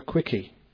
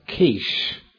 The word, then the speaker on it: quiche: When he first went out for lunch, he went to a bar, like a pub, and he asked the barmaid for a quickie. And the guy next to him said, "It's pronounced quiche."